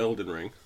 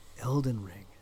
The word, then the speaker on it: Elden Ring: Before we get to Elden Ring. – You're saying it wrong. They never say Elden Ring. It's Elden Ring.